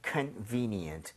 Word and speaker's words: convenient: Convenient. – Convenient. – VE에 강세를 찍고 발음하면 되겠습니다. 여러분 같이 한번 발음 해보도록 할게요. VE에 강세 주고요.